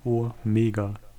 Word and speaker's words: Omega: Genau, ein Omega ist ein langes O und ein Omikron ist ein kurzes O.